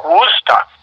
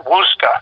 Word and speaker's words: Worcester: They call it WUSTA. they're Bostonians. – Okay. – So yeah, Worcester is pronounced Wooster.